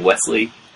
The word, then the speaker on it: Westley: I keep saying Wesley, but it's spelled in the film Westley in the credits.